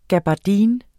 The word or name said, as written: gabardine